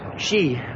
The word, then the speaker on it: Xi: Here's Trump talking about Chinese Prime Minister, President, President Z. How do you pronounce his name? Z? Chi? I'm not sure. – Xi. He's a great... – Xi. – Xi.